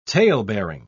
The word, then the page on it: talebearing: British English